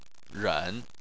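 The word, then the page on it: ren: tone 3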